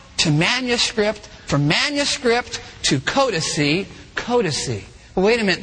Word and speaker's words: codices: Spell that C-O-D-E-X. In plural form, it's codice. Codice. It's spelled this way if you're taking notes, C-O-D-I-C-E-S. That's a codice.